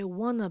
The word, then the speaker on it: want to: 那我们可以念快一点，我们把 want 的 t 和后面的 to 连音，可以念成 I want to be，I want to be。母语人士会把它念得更快，会变成 I wanna be，